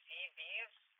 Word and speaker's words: Seabees: Have you heard of the CVs? Like, it's spelled SEABEES.